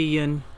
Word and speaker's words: Yeun: Okay, so it's Steven, and then last name is spelled Y-E-U-N, and he is South Korean American. Steve, is it Yun then? Win? – It should be Yun. – Yun.